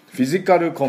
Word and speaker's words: physical: ですから、これが非常に効果を、出しているということなんですが、では早速発音の方行っていきたいと思いますが、今言いましたですね、フィジカルコンタクト、もうフィジカルっていうのはもうかなりもう日本語英語になってます。カタカナ英語ね、通じると思いますが、これもまたちょっと発音を直していこうと思います。フィジカルですね、まず PHY ね、 PH は F の音です。